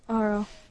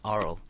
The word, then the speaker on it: aural: Because it is A after all, so I think it's pronounced Aural. – Aural. Alright. I've never even, like, heard that word. – Yeah, it's kind of like the opposite. – Until Wadge, but... – Basically, it's the opposite to oral, so you're listening. – Exactly. But the way you pronounce it still sounds like oral.